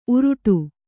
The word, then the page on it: ಊರಟೆ: ūraṭe; Kannada